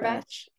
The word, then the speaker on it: Cumberbatch: Cumberbatch, Yeah.